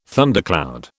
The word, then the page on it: thundercloud: British English